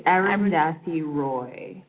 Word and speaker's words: Arundhati Roy: She's Arundhati Roy.